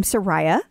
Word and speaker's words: Soraya: So over here, then you have, which I was told that you pronounce her name Soraya.